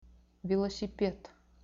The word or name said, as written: velosiped